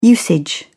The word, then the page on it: usage: British English